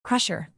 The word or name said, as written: pressure